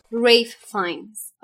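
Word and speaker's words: Ralph Fiennes: البته اسمش ریفه ریف فاینز آره با تلفظ درسته.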